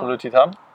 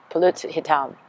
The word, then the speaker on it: pulut hitam: Am I going to totally mispronounce it? – No. – Are you going to make fun of me? – No. – Pulut hitam? – Very good. Pulut hitam. – Yeah. – How do we say it? How do we say it? – Pulut hitam. – Pulut hitam. – Is it right? You do it again? – Pulut hitam. – Yes, that's right.